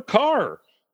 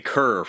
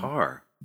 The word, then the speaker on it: Kerr: So we've been saying Kerr for the longest time. – Indeed. – But it's Carr.